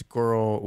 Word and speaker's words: GRRL: Up next, we have a song by Girl, and that is Girl with. It's spelled GRRL and it is stylized in all caps.